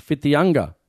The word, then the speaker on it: Whitianga: Jason, I'm in Z in a small town called Whitianga, pronounce mm. Fitianga.